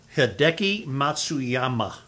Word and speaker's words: Hideki Matsuyama: Hideki Matsuyama.